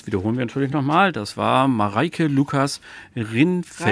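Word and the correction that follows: Rijneveld: Reinefeld. Reinefeld wird das ausgesprochen.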